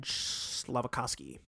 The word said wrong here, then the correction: Slavkovsky: That's far off. That's incredibly far off. – His last name is Slavkovsky. Slavkovsky.